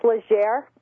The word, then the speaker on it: Legere: Well, in French, it's Legere. I don't know if he uses the French pronunciation, though.